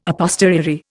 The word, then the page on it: a posteriori: Indian